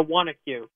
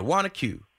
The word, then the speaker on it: Wanaque: Yeah, Wanaque. – Yeah, Wanaque.